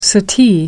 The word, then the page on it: suttee: American English